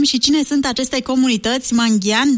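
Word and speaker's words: Mangyan: Da, așa se pronunță Mangian.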